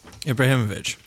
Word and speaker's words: Ibrahimovic: Hang on, how do I say it? Is it Ibrahimovic? – Ibrahimovic. – Ibrahimovic. Yeah.